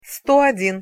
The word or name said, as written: sto odin